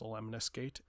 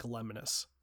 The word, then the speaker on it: Lemnis: I think it's pronounced Lemniscate. It's spelled L E M N I S, which looks like Lemnis. So I'm going to say Lemnis.